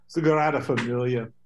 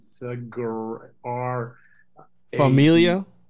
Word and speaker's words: Sagrada Familia: Sagrada Familia.